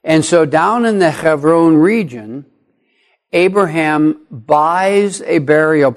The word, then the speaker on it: Hebron: When the beloved wife of Abraham, Sarah, dies, down in the Hebron region, many of us in our English tradition call it Hebron. Hebron is actually pronounced Hebron.